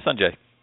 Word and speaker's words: Sunjay: Yeah, Sunjay.